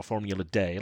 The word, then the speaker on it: Formula D: Formula D, which is sometimes pronounced, you know, Formula Day, like, you know, Formula One is pronounced Formula Un sometimes.